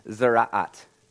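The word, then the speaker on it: tzaraath: Oh, before we go on, from now on, I'm going to try to use this word, zeraat. That is the biblical pronunciation of the actual word that is we now translated in all our Bibles as leprosy.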